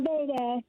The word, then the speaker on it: Nevada: Nevada.